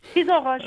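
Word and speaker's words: Frison-Roche: Frisson-Roche, pardonnez-moi, j'ai mal prononcé. Frisson-Roche. – Frisson-Roche, oui, comme l'auteur très connu.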